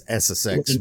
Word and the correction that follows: Exocet: missile. – Exocet from France.